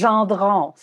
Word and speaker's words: Gendron: My surname is Gendron, so it's French.